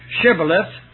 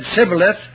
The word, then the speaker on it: shibboleth: Hebrew language has two letters equivalent to S. One is pronounced like S and the other like SH. And these from the other side of the Jordan couldn't pronounce shibboleth, and so when they stop one and say shibboleth, if he said sibboleth, Jethro said kill him.